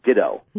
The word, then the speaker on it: Ditteaux: It's this cloned African cat, and they named it, and I'll spell it, they named it DITTEAUX. Again, it's New Orleans, a nice little French name there. – Cajun like context. – It. – But it's pronounced ditto.